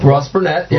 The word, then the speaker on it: Ross Barnett: There's a couple on here from the late '60s, '69 Rebel Invitational, Ross Barnett Reservoir. Or how they pronounce it? – Ross Burnett. – Yep. Ross Burnett.